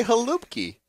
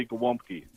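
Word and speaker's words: Gołąbki: All right, yeah, I just wanted to tell Count Panic, it's not Glomky, it's Glomky. The L has a slash through the middle. – You're right. – Pronounced W. – What do you say? Well, some people still say Halupki. – Well, that's more like a Slovakian more, but the Polish say Glomky.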